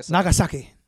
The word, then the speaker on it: Nagasaki: This is what took out Nagasaki. Did I say that right? – I don't know. – Nagasaki. Yeah, there you go.